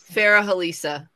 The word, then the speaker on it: Farah Halisa: Farah Halisa. – Yes, I was right. I'm sorry, I get excited. – The H is pronounced.